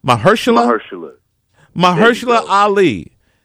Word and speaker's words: Mahershala Ali: And in it is Mahershala. – Did I say it right? – Mahershala Ali.